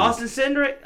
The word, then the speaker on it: Cindric: Austin Cindric.